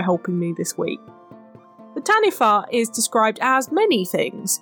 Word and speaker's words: Taniwha: Yes, this week we are looking at the Taniwha. I also must apologise preemptively. I pronounced this very incorrectly last week when I was announcing it, so this is the actual pronunciation in Maori, so just as a heads up.